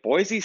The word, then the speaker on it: Boise: The locals say Boise. Boise. You don't say the Z. You say Boise.